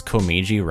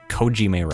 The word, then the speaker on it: Komeiji: And so that comes from the fact that, like, I was still trying to figure out how to pronounce Komeiji Records. Yep. I kept calling it Kojime Records for some reason.